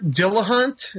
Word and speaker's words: Dillahunt: This Alan Cummings and Garrett Dillahunt.